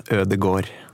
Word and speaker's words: Ödegård: Och jag måste ju inleda med att fråga, jag uttalade förmodligen inte ditt efternamn rätt, eller hur Magnad? – Nej, det är inte A-D-O, Ödegård.